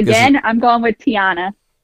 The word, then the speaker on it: Tiana: Tiana.